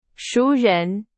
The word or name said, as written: 熟人